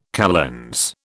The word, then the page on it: calends: British English